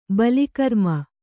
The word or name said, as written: ಬಲಿಕರ್ಮ